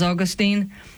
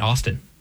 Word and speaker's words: Augustine: And yet, it was different when I was like first coming into the faith, because I was the person who was pronouncing the saint as Augustine and, being wrong. – I'm sure he is fine with... You know what? I know that he'd be fine with different pronunciations of it, because the English in older books just call him St. Austin. – Yeah. – They shortened it to Austin.